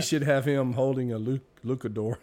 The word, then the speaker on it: luchador: Who came up with the name for the Lucafort? – Luchador.